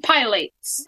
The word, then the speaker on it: pilates: Plates and pilates. – Put the accent on the second syllable.